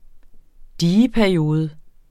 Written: dieperiode

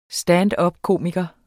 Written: standupkomiker

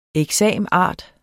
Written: exam.art.

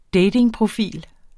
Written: datingprofil

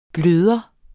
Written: bløde

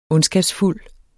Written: ondskabsfuld

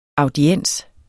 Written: audiens

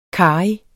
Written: karrig

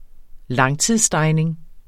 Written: langtidsstegning

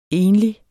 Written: enlig

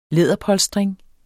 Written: læderpolstring

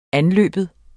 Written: anløbe

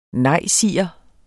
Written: nejsiger

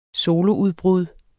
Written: soloudbrud

